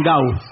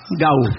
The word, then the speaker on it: Gal: Gal Costa le dicen Gau. Gau.